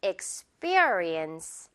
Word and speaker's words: experience: X, 폐 아니고요. B, B에 가깝게. Experience.